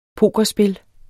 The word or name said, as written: pokerspil